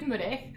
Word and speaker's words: Meurehg: My name is Melanie Meurehg, but it also depends who you ask, because my brother pronounces it differently, so whichever way you do it is perfect and beautiful. That sounds good. I do it Meurehg, and I help people awaken their hearts by creating businesses that are impactful, but also transform their lives and other people's lives.